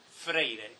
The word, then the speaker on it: Freire: Please note in the pronunciation here when Viviana says Frei, distinct from Freire. Paulo Freire, Brazilian educator, Eduardo Frei Montalva was the Christian Democrat president.